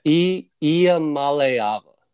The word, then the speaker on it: Iamaleava: Iamaleava.